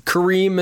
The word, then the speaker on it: Karim: It's Kareem is spelled K-A-R-I-M, so that's pretty easy. We got that Kareem, and then we think it's Mané. It's M-A-N-E. So we're going with Mané.